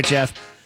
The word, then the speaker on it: Geoff: My name is Cabana Boy Jeff. I have an Instagram at Cabana Boy Jeff, and I spell it G-E-O-F-F like Jeffrey Draft from Toys R Us.